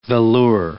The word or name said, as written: velure